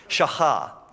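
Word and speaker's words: shakah (שחה): The Hebrew word, so I listened to how you pronounce this like 20 times just so I could impress you guys, okay? shakah. And then the Greek word is proskuneo.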